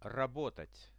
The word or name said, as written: rabotat